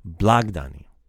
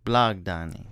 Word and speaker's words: Blagdani: And the other new one, Blagdani. – Blagdani. – Blagdani.